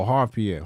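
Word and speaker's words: Harve Pierre: But he dropped the Y on his name? – Oh, Harv. Oh, Harv Pierre. Harv Pierre. – That's his name, Harv? – Yeah, I think it's Harv Pierre.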